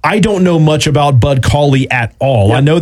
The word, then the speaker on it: Cauley: Bud Cauley, that was his first career win yesterday. Am I saying that correctly? – Yes. Yeah, you are.